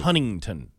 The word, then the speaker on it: Huntington: That's like uh, the people of Huntington. They don't pronounce the T sometimes. – It's right. Huntington.